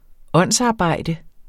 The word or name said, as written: åndsarbejde